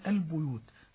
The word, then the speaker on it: البيوت: ويوجد فضيلة الشيخ بالنسبة للإخوة المبتدئين بعض الأخطاء الشائعة في كلمة البيوت، ففي رواية حفص عن عاصم هي بالضم، ولكن لشهرتها في الكلام العامي بالكسر ينطقونها مكسورة، فنريد أن نوضح الفارق والفاصل بين النطق الصحيح لرواية حفص عن عاصم وإلى النطق الخطأ الذي يقرأ خطأ رواية وليس تلاوة.